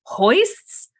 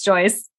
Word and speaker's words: joists: And I'm like, hoists? And they thought it was like funny the first time, but I genuinely didn't know how to say this word. My sister-in-law, who worked as an executive assistant for a construction company, like a huge construction company, was like, sis, it's Joyce.